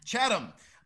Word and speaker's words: Chatham: I think I've asked you this before, but is it Chatham or Chatham? – It's Chatham. – Chatham. Yes. I have said your name wrong for a long time.